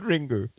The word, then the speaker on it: Ringu: It's like Ringu. – You pronouncing that in Japanese. – That's what the film's called, Adam. Sorry. – Is it? – Yes. – It's called Ringu.